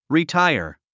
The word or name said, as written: retire